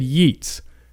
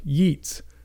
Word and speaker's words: Yeats: For years, I said Yeats because it's spelled Y-E-A-T-S, Yeats. It's Yeats, though, so don't make that mistake.